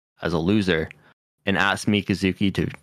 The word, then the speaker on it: Mikazuki: Yeah, and Mikazuki, if I say that correctly.